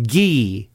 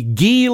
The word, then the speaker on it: Guy: Now, if he were a hockey player, we would be pronouncing his name Guy, would we not? It's not G-U-Y, if you're in Canada, pronounced Guy. It would be Guy Lombardo and his Royal Canadians. I'm sure of it. But here in the USA, he's Guy Lombardo.